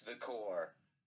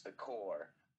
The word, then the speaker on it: Corps: It actually is pronounced The Core, but it's spelled CORPS. But it's pronounced The Core. I promise you, like the military corps or the Green Lantern Corps.